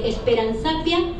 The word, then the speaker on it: Esperanzapia: Y después otro que es mi seudónimo, que es Esperanzapia Jimura. – ¿Cómo? – Es muy largo de contar. Pues es Esperanzapia con Z Jimura, con H muda.